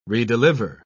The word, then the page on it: redeliver: British English